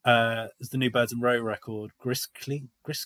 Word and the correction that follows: Gris Klein: Clean, Gris Klein, Gris Klein.